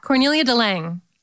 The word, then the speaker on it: Cornelia de Lange: Cornelia de Lange? – Yeah, Cornelia de Lange.